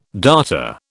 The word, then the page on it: darter: British English